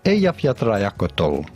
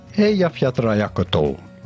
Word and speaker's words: Eyjafjallajökull: Eyjafjallajökull. It's actually way easier than you think. Let's have a look at how it would be spelled if we would spell it phonetically in English. Eyjafjallajökull, right?